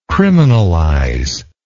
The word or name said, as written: criminalize